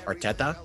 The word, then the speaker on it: Arteta: I'm going with Arsenal FC manager Mikel Arteta. Arteta? – Yep.